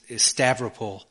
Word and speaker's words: Stavropol: I think the way you pronounce it is Stavropol.